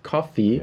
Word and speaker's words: Ko-fi: we have a Ko-fi, how is it pronounced? Coffee, yeah.